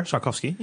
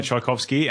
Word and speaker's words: Tchaikovsky: Tchaikovsky, yeah. – Tchaikovsky, and he put in there hashtag edumacated. – Yeah, so, I think it's the Waltz of the Flowers by Tchaikovsky.